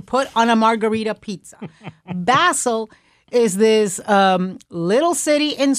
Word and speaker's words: Basel: And it is so important for people to pronounce it correctly, because there is no better way to prove your ignorance and uncouthness than saying Art Basel. Basil is what you make pesto out of.